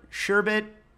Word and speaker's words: sherbet: I mean, it's sherbet. – Okay. – That's how it's spelled. There's no O back there. – I know that's how it's spelled, but I have always, my whole life, since I used to order it in from the 70s on, have said sherbert, like Bert and Ernie.